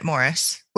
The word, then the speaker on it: Maurice: No, Colin Firth pronounces it Morris.